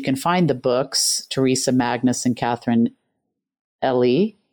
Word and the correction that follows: Ely: Am I saying that right? – Ely. Ely.